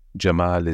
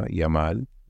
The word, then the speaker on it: Jamal: A huge, like, so, I mean, with us, we, Jamal is Jamal, and in Khalij, they don't pronounce the Ja, Yamal.